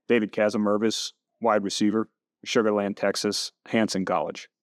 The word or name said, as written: David Kasemervisz